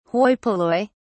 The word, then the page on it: Hoi polloi: British English